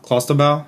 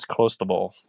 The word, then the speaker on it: clostebol: Um, and in that ball, it contained the banned substance substance clostebol. Clostebol? How do you say, it? You probably know. – So this one is Clostebol. Yeah.